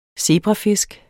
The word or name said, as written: zebrafisk